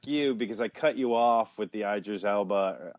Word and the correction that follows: Idris Elba: Idris, Idris, Am I saying that right? Idris, Idris, Elba.